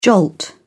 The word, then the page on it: jolt: British English